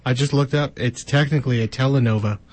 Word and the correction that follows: telenovela: telenovela. – Yeah, that's, yeah. Thank you for saying it right.